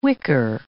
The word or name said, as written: whicker